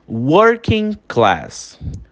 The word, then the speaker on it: working: Working class. Working class, a gente fala working class. Working class. Repara que o G, ele quase que é anulado, né? Na pronúncia. Working class, working class.